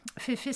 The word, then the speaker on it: Fefe: Fefe, ça s'écrit F-E-F-E, e, pour Fée en français. Féfé, c'est une marque qui a été lancée par euh, un, groupe qui s'appelle le Syndicat.